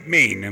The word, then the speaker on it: Maine: Maine.